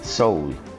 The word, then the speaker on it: Sowell: Sí, Thomas Sowell se escribe S-O-W-E-L-L, Sowell, pero se pronuncia Soul.